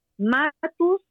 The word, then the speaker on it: Matus: Matus, mi apellido se escribe M-A-T-U-S y la letra B de dedo, Cami Matus D.